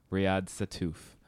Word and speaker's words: Riad Sattouf: Riad Sattouf.